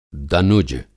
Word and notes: दनुज: Hindi

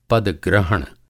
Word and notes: पद-ग्रहण: Hindi

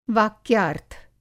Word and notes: वाक्यार्थ: Hindi

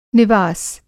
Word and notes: निवास: نواس

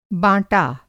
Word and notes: बाँटा: Hindi

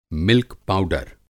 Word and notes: मिल्क पाउडर: Hindi

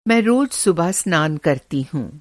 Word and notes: स्नान: Hindi

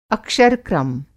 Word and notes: अक्षर-क्रम: Hindi

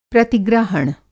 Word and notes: प्रतिग्रहण: Hindi

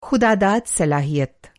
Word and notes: ख़ुदा-दाद: خداداد

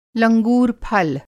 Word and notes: लंगूर फल: Hindi

